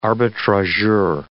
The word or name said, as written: arbitrageur